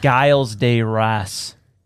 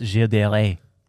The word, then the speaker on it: Gilles de Rais: S-Z-H? He's French. – I don't know. – Gilles? – It's spelled like Giles de Rais, but it's Gilles de Rais.